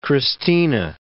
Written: Christina

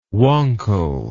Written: wankel

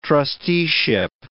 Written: trusteeship

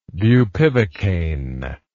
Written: bupivacaine

